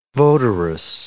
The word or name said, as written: votaress